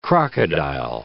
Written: crocodile

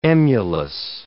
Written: emulous

